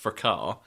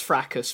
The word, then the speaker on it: fracas: The word is pronounced fracar. But that doesn't rhyme, so never mind. – I've heard it pronounced fracas before. – Yeah, by people who don't know how it's pronounced. – Okay. – It's a French word.